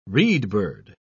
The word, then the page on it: reedbird: British English